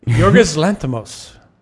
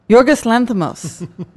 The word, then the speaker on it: Yorgos Lanthimos: Yorgos Lanthimos. – Yorgos Lanthimos.